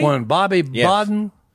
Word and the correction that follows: Bowden: Bowden. – Well, I can't say it right. – B-O-W-D-E-N.